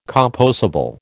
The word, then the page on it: compossible: American English